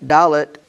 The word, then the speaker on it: Dalet: So the fourth letter of the Hebrew alphabet, Dalet, which is pronounced Dalet, that it is a cognate with the word Delet.